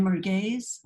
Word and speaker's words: merguez: It's lamb merguez sausage.